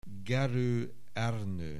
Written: Gerő, Ernő

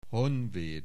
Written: Honvéd

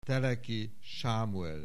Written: Teleki Sámuel

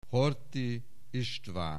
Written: Horthy István

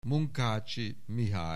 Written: Munkácsy, Mihály

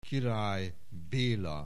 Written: Király Béla

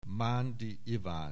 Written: Mandy Ivan

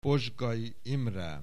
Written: Pozsgay Imre

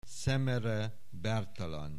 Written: Szemere Bertalan

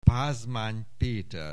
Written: Pázmány, Péter

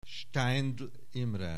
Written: Steindl Imre

